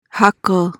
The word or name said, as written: hackle